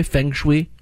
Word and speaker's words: feng shui: And the harshest critic around is going to see how her feng shui is. And that, of course, would be your husband, Kurt. Did I pronounce that right, by the way? Feng shui? – Feng shui.